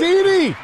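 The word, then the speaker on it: Penisini: Penicini! It's like penicillin with a cini.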